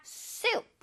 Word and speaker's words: soup: 수프할 때 수프가 아니라 soup. 선생님 입모양 보세요. soup.